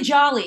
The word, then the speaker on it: Joly: It's actually Jolly. It's like Jolly. – Christmas. Yeah, Jolly.